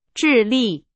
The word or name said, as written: 智力